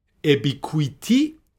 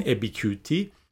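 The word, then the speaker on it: Ubiquity: ابیکویتی ای بی آی کیو یو آی تی وای از این نظر خوندم که شاید بخوایم برین شرکتش رو ببینین ابیکویتی ابیکویتی احتمالا تلفظ درستشه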